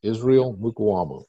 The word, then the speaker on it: Israel Mukuamu: Israel Mukuamu, I believe, is how it's pronounced.